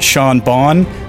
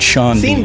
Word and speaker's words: Sean Bean: Is it Sean Bon or Sean Bean? No, it's Sean Bean.